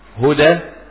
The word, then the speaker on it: هدى: وقد ترسم ألفا لا بأس، ولكن في الغالب بترسم ياء زي فتى، هدى، تقى. تمام. قل إن هدى الله هو الهدى. هدى تكتب هدى ازاي؟ بالياء، لكن تنطق ايه؟ ألفا.